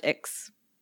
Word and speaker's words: Aix: Aix. It's pronounced Aix. Aix Marseille Université. Oui. AIX is pronounced X.